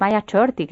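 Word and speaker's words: Maya Ch'orti': Como antropóloga también, Mado, incluyes un capítulo dedicado a los Maya Chorti, que no sé si lo pronuncio bien.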